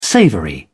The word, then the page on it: savoury: British English